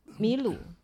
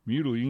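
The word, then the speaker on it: Milou: 好像白雪也叫做米罗，对不对？米鲁。 – 米鲁应该是法语发音吧。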